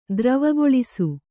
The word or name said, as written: ದ್ರವಗೊಳಿಸು